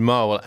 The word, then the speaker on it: Mhaol: That's, I think it's pronounced Mhol, M-H-A-O-L. It's a Gaelic word.